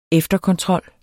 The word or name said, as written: efterkontrol